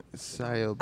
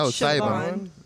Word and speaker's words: Siobhan: S-I-O. – We're going to call her Shehoba. – A-N. – Siobhan. – I think it's Siobhan. – Siobhan. – Siobhan. I would say it's Siobhan. – Siobhan? – Siobhan Fallon. – That is not how you say it. – Siobhan Fallon Hogan. – Siobhan. – Anyways. – Siobhan. That sounds like a real name.